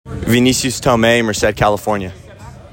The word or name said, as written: Vinicius Tome